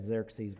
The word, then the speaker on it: Xerxes: Just for consistency, I'm going to read it as Xerxes, but that's why you might see it differently than on your page.